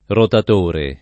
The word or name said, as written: rotatore